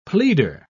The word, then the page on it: pleader: British English